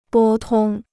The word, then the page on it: 拨通: bō tōng